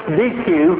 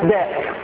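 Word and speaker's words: deque: Even though it looks like it's pronounced DQ, it's pronounced deck.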